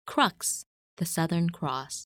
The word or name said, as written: Crux